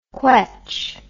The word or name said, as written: quetch